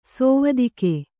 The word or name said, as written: ಸೇವತಿಗೆ